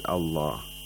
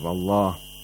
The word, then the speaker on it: Allah: Qulillah, the word Allah is pronounced with tarqiq because there is a letter with a kasra before the word Allah.